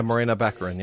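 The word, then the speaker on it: Morena Baccarin: Marina Baccarin. I think that's how you say her name. – Yeah, Marina Baccarin.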